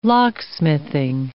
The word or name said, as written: locksmithing